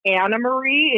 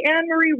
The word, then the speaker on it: Anne Marie: because my name is spelled Anne Marie, but it's pronounced Anna Marie. It's just, it's a Dutch name, a Dutch pronunciation.